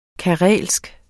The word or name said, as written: karelsk